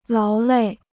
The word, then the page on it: 劳累: láo lèi